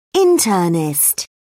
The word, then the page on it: internist: British English